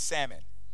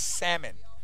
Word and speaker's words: salmon: And it's not salmon either. It's salmon. Of people coming up, it's salmon. Look it up in the dictionary. The L is silent.